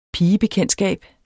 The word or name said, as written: pigebekendtskab